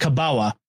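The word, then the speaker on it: kabała: which is looks like it has an L in it, but it's pronounced Kabawa.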